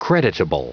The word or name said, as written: creditable